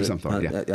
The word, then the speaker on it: Zumthor: We were talking in the lobby about one of your favorites, new guys, is Peter Zumpfor. Is that how you pronounce it? – He's Peter Zumpfor, yeah.